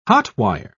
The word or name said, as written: hot-wire